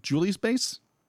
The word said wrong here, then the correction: Jules Bass: I think it's Jules Bass.